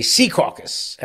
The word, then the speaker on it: Secaucus: And the mayor of Secaucus, New Jersey, Secaucus, as the locals say it up there, also not happy.